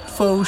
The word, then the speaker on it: pho: Oh yeah, I love pho. That's the star of Vietnamese cuisine. Pho show. – I'm gonna pretend I didn't hear that. Also, not to be that guy, but it's pronounced pho.